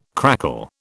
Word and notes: crackle: British English

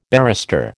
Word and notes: barrister: American English

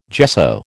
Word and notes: gesso: American English

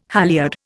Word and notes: halliard: Indian

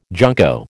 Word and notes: junco: American English

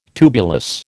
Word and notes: tubulous: American English

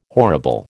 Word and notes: horrible: American English

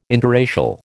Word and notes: interracial: American English

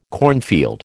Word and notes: cornfield: American English

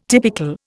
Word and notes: typical: Indian

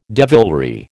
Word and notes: devilry: American English

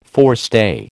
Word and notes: forestay: American English

